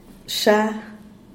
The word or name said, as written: sha